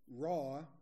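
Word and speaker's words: Raw (רעה): If you want to spell it, it's R-A-A-H, it's pronounced Raw, R-A-W, with another A-W, and that's the word for shepherd.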